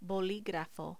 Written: boligrafo

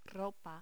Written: ropa

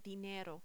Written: dinero

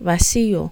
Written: vacío

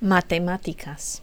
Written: matematicas

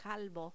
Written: calvo

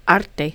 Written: arte

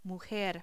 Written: mujer

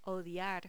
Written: odiar

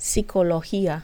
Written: sicologia

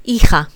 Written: hija

